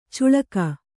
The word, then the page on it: ಚುಳಕ: cuḷaka